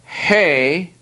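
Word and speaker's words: he (ἡ): Now, in the Greek, these are pronounced ho, he, to.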